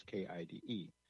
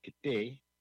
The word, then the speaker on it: KIDE: and the first project was KIDE. KIDE is spelled, well, KIDE. It's a Hoopa word. It's pronounced K'day. A lot of people see our call letters and call us Kide. No, it's K'day.